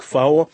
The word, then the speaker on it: ФАО: Саммит, созванный сельскохозяйственной и продовольственной организацией ООН, а в аббревиатуре он читается как ФАО, закрылся, не найдя структурного решения проблемы.